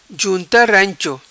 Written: junta rancho

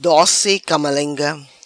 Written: Doci Camalenga

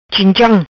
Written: Chincham

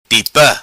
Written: Tipa

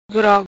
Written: Grog

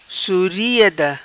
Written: Suriada

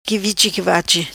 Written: qui vichi qui vachi

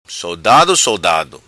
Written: Sodado Sodado

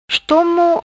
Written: Estomo

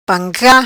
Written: Panca

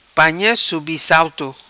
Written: Panha Subissalto

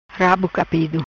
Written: Capido rabo

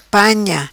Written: Panha

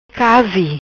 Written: Casi

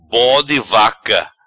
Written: Bode Vaca

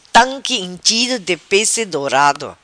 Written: Tanqui inchido di pesse dorado

